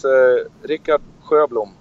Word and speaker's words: Rikard Sjöblom: It's Rikard Sjöblom.